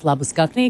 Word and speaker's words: Labuschagne: I mean, I think over there we start calling him Marnus Labuschagne because that's actually how you pronounce it when he gets back to South Africa.